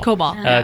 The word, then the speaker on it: COBOL: COBOL. – COBOL.